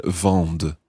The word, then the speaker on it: vendent: V-E-N-D-E-Z. – And the il, el plural form, il vendent, V-E-N-D-E-N-T. And remember that E-N-T at the end is silent. Il vendent.